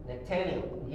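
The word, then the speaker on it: Nathanael: So he first find the, how do you say that name? – Nathaniel, yes.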